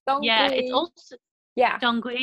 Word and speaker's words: dong quai: In all my research, I found that there was a herb called, I don't know how to say it, dong quai or dong gui. It's a Chinese herb. – Oh, yeah. – Dong gui, yeah.